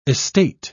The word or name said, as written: estate